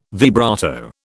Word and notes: vibrato: British English

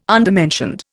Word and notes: undermentioned: Indian

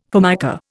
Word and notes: Formica: Indian

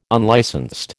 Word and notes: unlicensed: American English

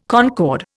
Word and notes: concord: Indian

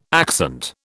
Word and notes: accent: British English

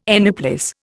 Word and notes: anyplace: Indian

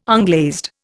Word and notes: unglazed: Indian

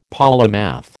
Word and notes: polymath: American English

